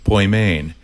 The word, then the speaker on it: Poimen: It's spelled P-O-I-M-E-N. Poimen is how we're pronouncing it, but it's really it's an English form of saying a Greek word, poimen. And poimen would be spelled P-O-Y, accent on the second syllable, M-A-N-E, Poimane.